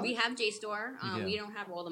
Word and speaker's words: JSTOR: Oh, Jstor. – Jstor. I always say it wrong. – You were saying Jester, and I was like, what? No, you have Jstor.